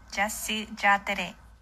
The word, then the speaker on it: Jasy Jatere: The pronunciation is Jasy Jatere. – Jasy. – So Jasy. – Yeah. So we're gonna try really hard to say it right.